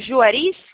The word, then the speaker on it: Juarez: Falo com o Juarez? Juarez? – Exatamente. – Pronunciei corretamente? – É, Juarez mesmo.